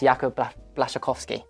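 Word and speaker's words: Jakub Błaszczykowski: I actually looked it up online, how to say his final name, and it's Jakub Blaszczykowski.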